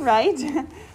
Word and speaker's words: right: The W is silent. So we don't say, what, right? We say, right. So that's the one part of the word we need to know by heart.